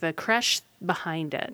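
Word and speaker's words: crèche: It's spelled C-R-E-C-H-E. – Okay. – Creche. And the E has a circumflex over it if you know French.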